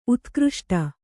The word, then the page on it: ಉತ್ಕೃಷ್ಟ: Kannada